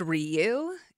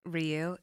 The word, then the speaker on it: Ryu: And did you know that it's pronounced Ryu? It is.